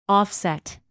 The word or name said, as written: offset